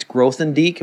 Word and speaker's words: Grothendieck: I've heard it said that when it's Grothendieck, am I saying that right? Yes.